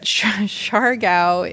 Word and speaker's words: Siargao: I don't really want to share this, but Siargao is in the south. It's where I went surfing. – Siargao? – Yeah, it's spelled S-I-A-R-G-A-O.